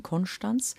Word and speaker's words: Konstanz: Seenachtsfest in Konstanz, äh, Konstanz, man muss es alemannisch aussprechen, Konstanz, das ist ja Größe am See.